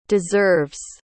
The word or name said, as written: deserves